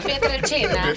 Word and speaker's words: Pietrelcina: Bajo el nombre de San Pío de Pietrelcina. Por favor, pronúncialo bien tú que eres italiana. Muchas gracias. – Pietrelcina.